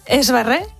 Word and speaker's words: Sverre: Estaba Haakon, estaba Pentemarit y estaban sus dos hijos, la princesa Ingrid y el príncipe Sverre. Creo que se pronuncia así. – Sverre.